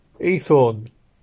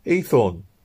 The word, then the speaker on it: Haythorne: "My name's Ethorn." – "What?" I asked. – "Ethorn, sir. Ethorn." – "Spell it." – H-A-Y-T-H-O-R-N-E.